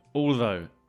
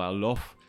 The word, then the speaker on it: although: Although, A-L-T-H-O-U-G-H. Notice the pronunciation, not allow or alof. Although.